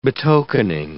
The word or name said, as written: betokening